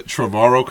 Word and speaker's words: Trevorrow: Are you saying to release the Trevor outcut? – The Trevorrow cut. – However you pronounce it.